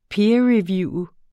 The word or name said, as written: peerreview